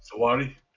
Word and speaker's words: Sahrawi: Sahrawi? – Sahrawi.